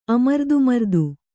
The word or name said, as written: ಅಮರ್ದುಮರ್ದು